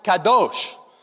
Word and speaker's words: kadosh: The word holy in Hebrew, it's an adjective, and it's a word that's pronounced kadosh.